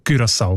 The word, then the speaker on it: Curaçao: Die haben nämlich gerade letzten Dezember die Aussprachempfehlung geändert von Curaçao auf Curaçao, weil sie beziehen sich dort auf einen Aussprachenduden und auf das digitale Wörterbuch von der deutschen Sprache, wo beide eben Curaçao vorschreiben. – Danke vielmals. Jetzt hast du mir den Namen von der Insel gesagt, wie man den ausspricht. Curaçao mit Ü in dem Fall.